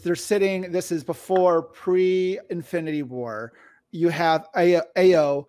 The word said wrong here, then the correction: Ayo: Uh, am I saying her name right? – Ayo, I believe.